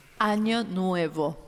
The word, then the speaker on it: Año Nuevo: Well, Ana Nueva, Nuevo. – Año Nuevo.